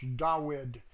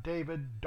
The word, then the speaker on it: Dawid: But the scholarly study material I have says it's pronounced Dawid, D-A-W-I-D, not David, Dawid.